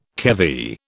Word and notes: keV: British English

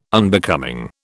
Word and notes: Unbecoming: British English

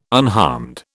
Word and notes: unharmed: British English

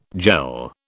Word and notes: jell: British English

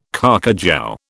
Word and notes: carcajou: British English

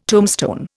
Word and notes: tombstone: Indian

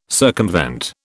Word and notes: circumvent: British English

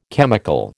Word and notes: chemical: American English

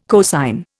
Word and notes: cosine: Indian

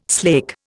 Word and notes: slake: Indian

